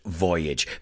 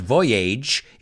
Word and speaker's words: voyage: And its maiden voyage is its first voyage. Fijaos en la pronunciación, no es voyage, it's voyage, que rima con bridge.